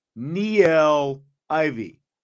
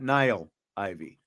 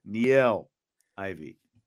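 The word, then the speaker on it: Niele Ivey: Can we get the actual pronunciation on her name? He is speaking of Niel Ivy, not Niall Ivy. Niel Ivy.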